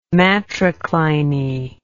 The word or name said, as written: matricliny